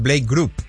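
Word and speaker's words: Blake Grupe: Ya lo contrataron y viene de los New York Jets, de procedente del equipo de prácticas, Blake Groupe. No sé si se pronuncia así o es Blake Group.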